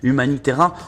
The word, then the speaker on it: Humanitera: Oui, non, on le prononce Humani, parce que Umani. Le U, c'est en Corse, c'est un U, mais bon, Humanitera,